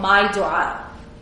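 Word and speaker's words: MyDuaa: And that is that you get your phone and you download an app called My Dua. It's spelled M-Y-D, as in David, U-A-A. So there's two A's. My Dua.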